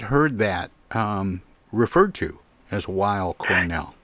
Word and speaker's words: Weill: You're also on the faculty at Cornell, and I have to ask, is it pronounced Weill Cornell Medical College? – Weill. – Okay. I worked at a public radio station hosting classical music once, and I remember being taught to say Kurt Weill, which is spelled the same way, and I thought, I wonder if that's true here as well.